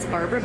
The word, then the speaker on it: Barbara: It's Barbara Bittner, and it's spelled B-A-R-B-A-R-A, and my last name is B-I-T-T-N-E-R.